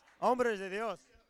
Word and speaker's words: Hombres de Dios: And these four men of God, these hombres Dios, or how do you say it? Say it for me, senor, por favor. – You messed me up. – Hombres de Dios.